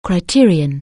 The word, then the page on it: criterion: anglais